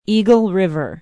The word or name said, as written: eagle river